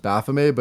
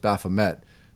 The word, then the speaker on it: Baphomet: So when I looked at the pronunciation, they said originally it came out in like France, so it was pronounced Baphomet, but if it's said in English, it's often pronounced Baphomet. So it can be either one.